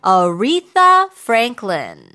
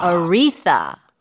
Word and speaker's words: Aretha Franklin: 자, 정답 지금부터 문자로 주시고 한국에서는 아레사 프랭클린이라고 하거든요. – 네. – 정확한 발음이 어떻게 돼요? – Aretha Franklin. – 아. – Aretha. 완전 너, 다르죠? – 너 참 나이가 Aretha.